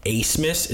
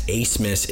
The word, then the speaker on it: Abmas: Max Asmus is how you pronounce his last name. The spelling is A-B-M-A-S. So people are going to get that massively wrong throughout the tournament, like just general people.